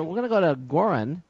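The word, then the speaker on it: Göran: it's pronounced a little more like Joran.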